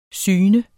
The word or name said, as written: syn